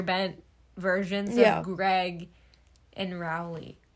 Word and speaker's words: Rowley: Rowley? – Yeah.